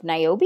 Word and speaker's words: Niobe: So, she revealed that she was offered the role of Niobe. Is that how you say it? Okay, cool.